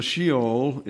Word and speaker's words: Sheol: Now, we pronounce Sheol, it's Hebrew. So we have Sheol, and we have the Koine Greek, Hades.